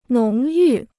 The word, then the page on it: 浓郁: nóng yù